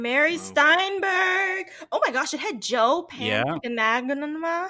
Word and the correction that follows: Mantegna: Joe Mantegna? – Yeah, he was from Criminal Minds.